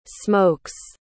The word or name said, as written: smokes